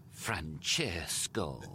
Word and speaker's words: Francesco: And don't say it like that. Francesco. Pouting. It's 3 syllables, not 10.